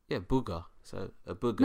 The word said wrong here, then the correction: bulgur: Not booger. Bulgur. – Booger. Just yes. – Because. You know, B-U-L. G-U-R. – So that sounds... That's spelled like burger, yeah? – No. B-U-L.